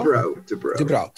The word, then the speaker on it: Dubrow: So Kevin Dubow, is that how you pronounce it? Is it Dubow? – Dubrow. – So Dubrow.